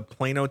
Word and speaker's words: Plano: Gale is spelled G-A-Y-L-E. – Okay, and you're primarily working out of the Plano, Texas area?